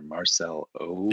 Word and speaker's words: Marcelo: But it's like there's an O in there. Marcel O. – There is an O, yeah. Marcelo.